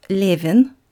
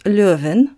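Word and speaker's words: Levin (Левин): Before giving you a brief summary of the sixth chapter, I'd like to mention, in order to avoid further confusion, that I pronounce Levin here as Levin, not Löwen, you might hear in some movies or other narrations. It's that constant linguistic argument about the letter Yo.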